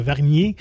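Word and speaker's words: Wargnier: L'offrande française de la semaine, la réparation de Régis Vernière. Vernier. – Comme ça qu'on le dit. – Je pense que Vernier, oui. – C'est un W qu'on prononce comme un V.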